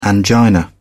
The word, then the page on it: angina: British English